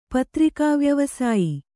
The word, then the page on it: ಪತ್ರಿಕಾವ್ಯವಸಾಯಿ: patrikā vyavasāyi